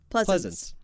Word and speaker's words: Pleasence: Pleasence. – I think that's how you say his name? – Pleasence. – Pleasence. – Yep.